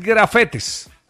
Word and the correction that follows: gafete: Ah, ok. – Es gafete.